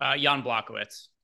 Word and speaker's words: Jan Błachowicz: And then another one that's got a pretty tight spread that I think could end up having absolute crazy upside, Pereira and Jan Błachowicz. Is that how it's said? Is that correct? – Jan Błachowicz.